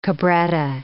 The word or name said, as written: cabretta